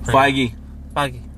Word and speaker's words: Feige: Yeah, they're planning on getting their own Kevin Feige. – Right. – I think I said his name right. – Feige.